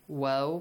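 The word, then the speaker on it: واو: Waw, pronounce it wa, not va.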